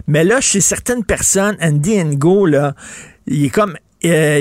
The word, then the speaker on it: Andy Ngo: Et là, tu vas nous parler d'un personnage très, très controversé, Andy Ngo.